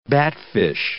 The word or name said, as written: batfish